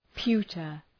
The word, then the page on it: pewter: ‘pju:tər